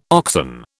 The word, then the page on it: oxen: British English